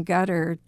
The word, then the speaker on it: Ghadar: So even the name Gutter, G-H-A-D-A-R, sounds like gutter. – Exactly. – Like on your house.